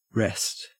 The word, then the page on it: wrest: Australian English